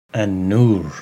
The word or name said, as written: An-Nur